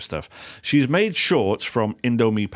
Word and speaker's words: Indomie: including some shorts made out of Indomie packaging.